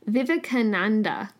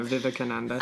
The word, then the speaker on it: Vivekananda: the letter that I had as a primary resource from this Swami Vivekananda, I'm not saying that right. I feel like it's Vivekananda. That's it. Sorry. – Yeah, Vivekananda. – I'm missing a syllable again.